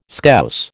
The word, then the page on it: Scouse: American English